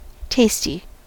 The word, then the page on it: tasty: American English